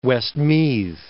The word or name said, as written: Westmeath